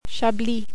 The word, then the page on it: Chablis: French